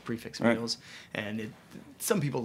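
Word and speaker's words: prix fixe: Is there a reason more restaurants don't do a prefix? I always find I like the pricing of it. Like, Thanks for pronouncing the X. People say prefi. I'm like, it's there's an X.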